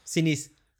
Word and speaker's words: Sinise: Eh, Gary Sainis. Gary Sainis. Como queréis, Sinise. – Eso. Sinise, no sé cómo se pronuncia Sinise.